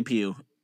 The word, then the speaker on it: Pugh: Puff. – Most people say Pew, and I'm going to lead with the majority.